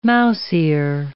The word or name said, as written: mouse-ear